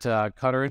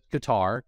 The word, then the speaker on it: Qatar: He's Group Chief Digital Officer at Qatar Insurance Company. Some pronounce it Qatar, Q-A-T-A-R, but my friends who lived there all like made me change the way I say it to Qatar.